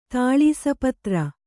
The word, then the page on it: ತಾಳಿಸಪತ್ರೆ: tāḷisa patre; Kannada